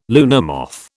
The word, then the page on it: luna moth: British English